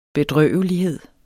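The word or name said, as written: bedrøvelighed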